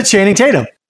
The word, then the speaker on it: Channing Tatum: I said Channing Tatum.